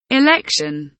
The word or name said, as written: election